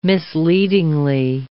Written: misleadingly

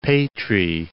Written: Patrae

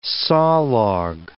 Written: sawlog

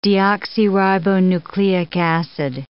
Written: deoxyribonucleic acid

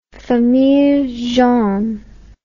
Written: famille jaune

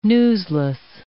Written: newsless

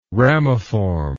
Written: ramiform

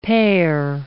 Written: pare

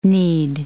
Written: knead

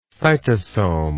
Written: cytosome

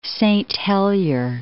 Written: Saint Helier

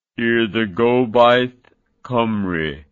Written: Urdd Gobaith Cymru

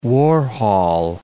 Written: Warhol

